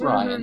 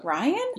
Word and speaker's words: Rian: It's just Ryan, mm-hmm. but... – Ryan? – Yeah. – Why the fuck is it spelled like that, then? – It's cool.